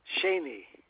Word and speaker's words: Shaney: Shani, S-H-A-N-E-Y. – Shani.